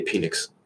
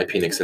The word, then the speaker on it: Penix: I know it's pronounced Penix, but I'm going to say Penix because I'm immature like that, and it's just funny.